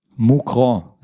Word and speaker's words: Mouscron: Ja, wie spricht man den Verein aus? Denn du bist da, Co-Trainer gewesen, tatsächlich in der ersten Liga, glaube ich, ne? – Ja. Moucron, Das französische Teil in Belgien, 10 Minuten von Lille, also war schon Frankreich eigentlich.